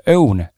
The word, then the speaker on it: Õun: päris keeruline Tallinnas, äh, Tallinna poisil seda hääldada, ma arvan, et saarlastel tuleb see hääldamine parem välja, aga Õun, Ö-U-N, mis on siis tõepoolest õuntest valmistatud mahedad energiat ja immuunsust toetavad käsitööjoogid.